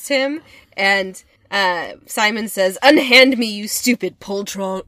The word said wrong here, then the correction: poltroon: or I probably should have looked up the pronunciation. – I think it's poltroon. – Yeah. "Unhand me, you stupid poltroon."